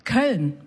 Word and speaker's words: Köln: Did I say that right? – Köln. Yeah. Köln.